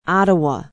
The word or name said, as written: ottawa